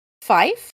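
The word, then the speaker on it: Fyffe: Now, Fife, and I think I'm saying that right, guys. It's F-Y-F-F-E. Why? Don't know. Like, literally nobody knows. I tried to look at it. Fife is the UFO capital of Alabama.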